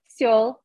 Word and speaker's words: Seoul: Although, am I pronouncing it correctly? – Seoul. – Yes.